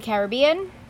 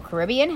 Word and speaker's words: Caribbean: Islands of the Caribbean, or Caribbean, however you wish to pronounce it. Both are correct.